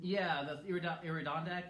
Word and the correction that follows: Iditarod: I can't pronounce it. – Iditarod, thank you.